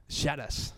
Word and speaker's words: Charas: Jo, per acomiadar-me, porto un grup que canta en anglès, es diu, a veure si ho sé pronunciar, això es diu Charas, Charas, com cucharas, no? – Charas.